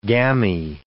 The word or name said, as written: gammy